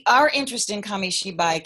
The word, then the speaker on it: Kamishibai: It's a Japanese word, so it's difficult, but it's called Kamishibai. – Yes, thank you. I knew we would need a pronunciation help on that one. Kamishibai.